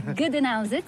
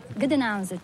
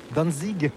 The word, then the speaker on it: Gdańsk: Gdańsk, c'est le nom slave. Gdańsk. – Gdańsk, que nous, Français, prononçons en disant Danzig.